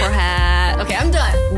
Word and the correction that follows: curhat: Nope. It's pronounced curhat. – It's an Indonesian term. It literally means rambling about your feelings.